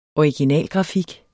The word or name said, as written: originalgrafik